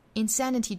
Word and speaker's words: insanity: The distance between insanity, insanity 重读音节在第二个音节上， insanity.